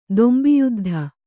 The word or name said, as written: ದೊಂಬಿಯುದ್ಧ